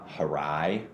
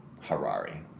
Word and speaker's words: Harari: It's called Sapiens by Yuval Noah Harari. – I've heard a lot about it. – Harari. Sorry, I mispronounced that last name there.